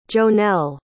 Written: jonell

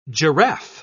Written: giraffe

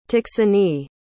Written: taksony

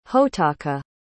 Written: hotaka

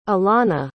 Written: alannah

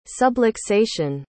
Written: subluxation

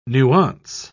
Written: nuance

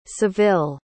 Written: seville

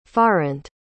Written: farrant

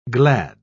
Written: glad

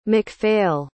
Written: mcphail

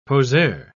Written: poseur

